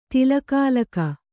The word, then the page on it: ತಿಲಕಾಲಕ: kannada